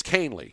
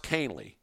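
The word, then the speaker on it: Kahnle: Is it Canley or Conley? – It's Canley. Okay. It's pronounced Canley.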